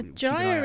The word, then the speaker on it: gyre: G-Y-R-E. – It's pronounced Gaia. – Gyre, it's like gyroscope. – Arissa looks over her librarian glasses that have appeared on her face. It's pronounced gyre.